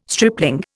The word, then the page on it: stripling: Indian